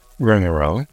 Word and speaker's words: romarin: Rosemary, or as it's known here in France, Romarin, is spelt in French R-O-M-A-R-I-N.